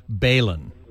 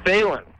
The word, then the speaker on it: balun: And I've been experimenting with different balloons to transmit the HDMI signal through the Ethernet cables. – Sure. Just before you get any further, it's pronounced Balun. – Balun. – Balun. It's B-A-L-U-N for balanced, unbalanced.